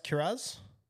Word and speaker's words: Kiraz: The next player, I know you're interested in him, 605K, Jacob Kiraz. Is it pronounced Kiraz? – Kiraz. – Kiraz. – No, you said it fine.